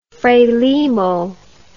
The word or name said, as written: frelimo